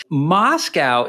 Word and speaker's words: MoSCoW: MOSCOW is actually an acronym where the O is silent.